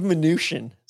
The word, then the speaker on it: Mnuchin: Steve Mnuchin.